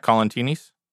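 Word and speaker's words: Colantoni: And Enrico, how do you say his last name? Colantinis? – Sure. – Colantinis? Colantini? – Cavatappi. – Colantini. – Sure. Colantini. Enrico Colantini.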